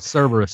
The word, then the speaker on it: Cerberus: and one of them was that Cerberus, whatever you pronounce it, the three-headed dog of the underworld, Hades' dog. – Yeah, Cerberus.